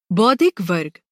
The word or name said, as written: बौद्धिक